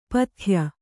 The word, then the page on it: ಪತ್ಯ: patya; Kannada